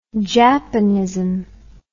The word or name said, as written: japonism